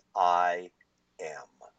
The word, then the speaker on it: IAAM: If we break down the acronym, it's all about me, we come up with IAAM. And when we pronounce that, it's I am.